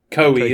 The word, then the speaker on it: Koei: How do you pronounce this again? It's Koei. Koei, isn't it? Yeah, I knew that.